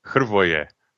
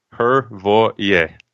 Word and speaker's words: Hrvoje: You know, Hrvoje. Hrvoje. – See, you're rolling R's already, so already it's going to be Hrvoje.